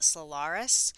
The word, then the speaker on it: Solairus: Another tip I have, a hot tip, is think about applying as a contractor to a company called Solaris, or some people call it Solaris, and it is spelled S-O-L-A-I-R-U-S, and their website is solaris.aero, A-E-R-O.